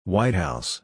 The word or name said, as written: White House